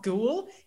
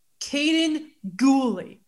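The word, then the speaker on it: Kaden Guhle: It is, Mike is wrong here. It's not Ghoul. It's pronounced Kaden Gooley.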